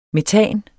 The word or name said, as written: metan